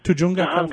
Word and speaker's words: Tujunga: And folks, here we have my friend Gerald LeBaron calling from, how do you say that? Pronounced Jujunga, California? – Tujunga, California.